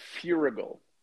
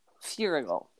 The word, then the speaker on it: Feuerriegel: Uh, Feuerriegel. Feuerriegel. Feuerriegel.